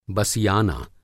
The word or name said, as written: बसियाना